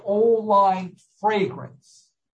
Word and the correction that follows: Old Vine Fragrance: Old Vine Fragrance.